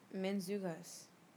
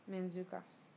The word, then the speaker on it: Mantzoukas: He also... Mantzoukas? – No, it's Mantzoukas. – Mantzoukas? – Mantzoukas. – Mantzoukas, okay.